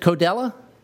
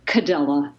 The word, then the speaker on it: Codella: Is it Codella? Yeah, a lot of people do pronounce it that way, but it's really Cadella.